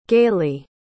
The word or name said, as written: galey